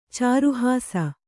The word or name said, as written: ಚಾರುಹಾಸ